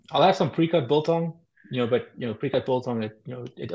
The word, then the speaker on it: biltong: Biltong. – Biltong.